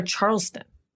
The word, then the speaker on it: Charleston: Calls headmaster Charlton and says, did I say that right? Headmaster Charleston. – I think you forgot the S.